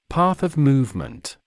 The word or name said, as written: path of movement